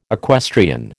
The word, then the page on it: equestrian: American English